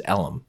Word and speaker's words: Ellum: E-L-M, Elm? – That's how I would say it, but everybody out there says Ellum.